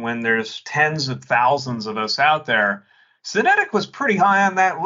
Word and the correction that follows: Synetek: And it's Synetec. – Synetec. I mispronounce it every time.